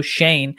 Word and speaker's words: Shane: I don't know why I keep thinking his name's pronounced Sean, but anyway, it's Joe Shane.